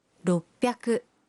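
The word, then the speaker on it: roppyaku (ろっぴゃく): 600 se convierte en roppyaku.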